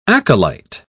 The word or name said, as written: acolyte